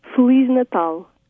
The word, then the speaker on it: Feliz Natal: Feliz Natal. – Feliz Natal.